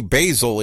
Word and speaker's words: Basil: I've heard people messing with her last name, calling her Basil and other things, but Tony Basil is the accepted pronunciation according to Google.